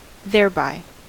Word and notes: thereby: American English

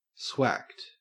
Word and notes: swacked: Australian English

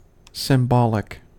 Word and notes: symbolic: American English

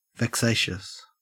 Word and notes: vexatious: Australian English

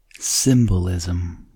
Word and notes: symbolism: American English

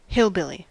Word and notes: hillbilly: American English

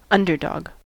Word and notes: underdog: American English

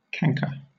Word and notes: canker: British English

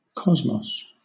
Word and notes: cosmos: British English

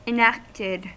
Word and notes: enacted: American English

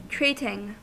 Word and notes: treating: American English

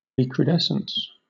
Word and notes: recrudescence: British English

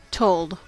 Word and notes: told: American English

